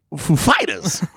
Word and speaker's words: Foo Fighters: how does the pronunciation inflection happen on the Foo or the Fighters, and Dave Grohl knows that Chris Walken has that way about him, so he says it's on the Fighters.